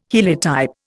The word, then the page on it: heliotype: Indian